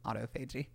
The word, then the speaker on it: autophagy: it's, it, the correct pronunciation is autophagy.